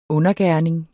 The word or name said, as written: UNDERGERNINGEN